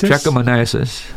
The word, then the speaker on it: trichomoniasis: Trichomoniasis.